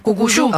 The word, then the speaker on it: Cucuyú: Cucuyú, Cuculú. Se escribe Cucu. LLU con acento en la U. Cucuyú.